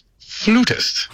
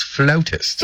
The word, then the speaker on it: flautist: Proper English and pronunciation states that in America, they pronounce it flutist. But yet, for Canadians and British and Australian, it is preferred to be pronounced as flautist. – Flautist! – Although both are acceptable here in Canada, we choose the word flautist.